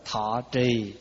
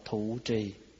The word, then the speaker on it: thọ trì: À, rồi thí dụ mình nói là thọ trì thì ngoài Bắc đọc là thụ trì.